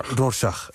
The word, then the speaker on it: Rorschach: Hij is schrijver, performer, presentator, stemacteur en medeoprichter van uitgeverij Rorschach. Zeg ik het goed? – Rorschach.